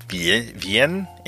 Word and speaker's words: Wien: But the people of Vienna, spelled W-I-E-N, huh? Wiener, get it? Vienne, that's how you spell it in German.